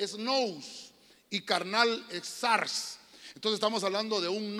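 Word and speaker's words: sars (σάρξ): Esa palabra se, pronuncia sars como esa palabra sars Es hablar de una mente carnal.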